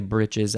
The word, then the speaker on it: breeches: Dutchman's breeches. I think it's actually britches. I just said, because it's spelled breeches.